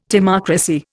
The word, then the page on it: timocracy: Indian